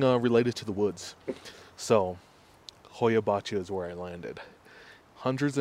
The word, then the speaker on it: Hoia-Baciu: Hoya Bachu. – Oh, that sounds like an evil spell. – Yeah, Hoya Bachu is when you look at a like world's creepiest forest.